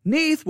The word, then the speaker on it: Neith: That's N-E-I-T-H. It's pronounced Neith, N-E-E-T-H is how it's pronounced, Neith.